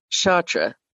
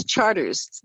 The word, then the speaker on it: Chartres: For example, you don't say Chartres Street in New Orleans. It's Charters there. It's Charter Street.